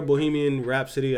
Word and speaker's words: Bohemian Rhapsody: When I hear Bohemian Rhapsody, I said that right? – Yes.